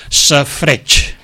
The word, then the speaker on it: safreig: Moltes vegades aquesta vocal tendeix a desaparèixer. Es pronuncia amb poca força davant de la següent, que és la tònica forta, i desapareix. Per exemple, safreig.